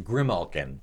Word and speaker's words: Grimalkin: M-A-L-K. And a color. – Grimalkin? – Grimalkin, yep. Yep. This one is pronounced Grimalkin. It's G-R-I-M.